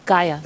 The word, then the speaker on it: Gaia: Pronounced Gaia? – Gaia. – Spelled G-A-I-A.